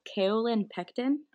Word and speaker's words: kaolin pectin: And then that product I had to look up, I always say it wrong. It's kaolin pectin.